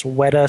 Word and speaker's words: Weta: We had probably badly pronounced this, Weta Studios. – Nope, you got the guys that do Weta the all the special effects for Lord of the Rings.